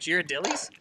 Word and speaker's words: Ghirardelli: Ghirardelli's going to win this. Ghirardelli's going to win this. – The winner is that how you say it? Ghirardelli's? – Yes.